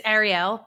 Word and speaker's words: Arielle: It's Arielle, but it's very much a New York Arielle accent.